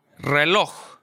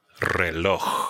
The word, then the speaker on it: reloj: Reloj. – Reloj.